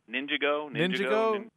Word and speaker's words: Ninjago: Is it Ninjago? Is it a soft J? – Ninjago. Yeah, I don't know.